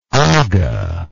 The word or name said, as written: agha